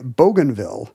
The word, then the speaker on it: Bougainville: Even though the French pronunciation would be Bougainville, apparently locals say Bougainville.